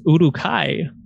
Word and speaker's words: Uruk-hai: And so there are purple orcs, also known, and I think it's Uruk-hai, which is how it's pronounced in the Lord of the Rings trilogy.